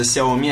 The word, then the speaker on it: Xiaomi: Не, я, кстати, опять же, я каждый раз тебе это повторяю, что наши вот эти чуваки, которые топят за Xiaomi, они, во-первых, не знают, что ударение на последний слог. Да, они знают, что не кс, а просто надо.